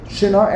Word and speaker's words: Shinar: If it will enter your mind, l'leches to go, v'lichboish to conquer, eretz shinor. Am I pronouncing it correctly? Shinor, the shin? The shin? Shinor, eretz shinor. – Yeah.